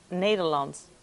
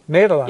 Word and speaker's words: Nederland: Nederland. – Nederland. – Nederland. Hey. – Nederland.